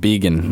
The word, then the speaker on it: Begin: Oh, David Began.